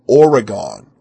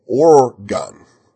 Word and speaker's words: Oregon: He had a Western accent, but he mispronounced Oregon. He said Oregon, and Oregonians say Oregon.